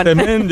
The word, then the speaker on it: Msemen: Msemen, yo que sé, en otros lugares se conoce como ergaif. Es una palabra complicada, decías M-S-E-M-E-N. Msemen, msemen, vale. Y se pronuncia como lo ha hecho Hakima. Pero nunca, nunca se pronuncia como lo vamos a escuchar en boca de este tiktoker, porque puede ocasionar confusiones.